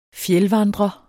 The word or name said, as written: fjeldvandrer